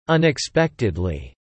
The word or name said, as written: unexpectedly